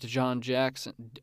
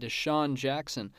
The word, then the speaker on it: DeSean Jackson: Carson Wentz threw a touchdown to DeSean Jackson. Oh, I'm sorry. I totally butchered that. DeSean Jackson for 51 yards.